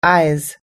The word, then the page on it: eyes: American English